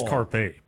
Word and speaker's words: carpe: She said carp pumpkin. – I'm pretty sure it's carpe pumpkin. – Oh, well, excuse me. I'm sorry. You, the purveyor of pronunciations here in the radio station. Yes, it is carpe.